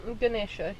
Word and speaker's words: Ganesha: First of all, before we get started, I want to give a big thanks to, apologies if I pronounce this word wrong, Ganesha Project Hippie Bus. – Ganesha, yes.